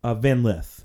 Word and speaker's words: Van Lith: Van Lith.